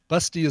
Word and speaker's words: Basti: Busty is the phonetic version for the German name Basti. B-A-S-T-I, but it's pronounced Busty.